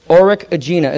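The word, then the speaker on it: Urukagina: Uruk Agina is the way it's pronounced, of Lagash.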